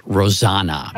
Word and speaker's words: Rosanna: But Patricia pointed out to me that her sister's name is really pronounced Rosanna.